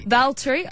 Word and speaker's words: Valtteri: Valtteri.